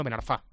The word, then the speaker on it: Ben Arfa: No es Ben Arfa, sino Ben Arfa, con acento final en la A, es palabra aguda.